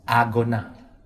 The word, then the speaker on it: agona: Now, in the original language, the word race is pronounced agona. Now, do you know of an English word that sounds like that? Agony.